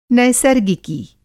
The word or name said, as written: नैसर्गिकी